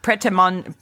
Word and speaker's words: Prêt-à-manger: How do you pronounce that? – It's manger. Manger. – Manger. – But I think yours is better. – Manger. – Prêt-à-manger.